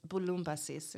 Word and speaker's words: Bouloubasis: Okay, so will you actually pronounce it in Greek? – Yeah, it's Bouloubasis in Greek. – How have other people butchered it in the way that I have? – Well, everyone thinks bouillabaisse, yeah.